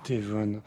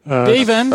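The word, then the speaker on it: Devuan: Devon. – Devon. – À prononcer à l'italien.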